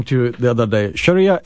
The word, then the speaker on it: Sharia: you apply the idea of Sharia law to Sharia, as you guys pronounce it, rolling the R, Sharia.